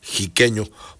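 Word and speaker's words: xiqueño: Aquí dice: ojalá y tengan grabado ese huapango jiqueño que se escribe con X y se pronuncia con J, jiqueño, por ser de Xicotepec de Juárez, dice el 3030.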